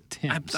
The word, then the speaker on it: Thames: It's spelled like Thames. I think it said Tim's.